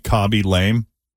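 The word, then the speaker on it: Khaby Lame: Know if I'm pronouncing, Cobby? – Is K-H-A-B-Y, Cobby Lame.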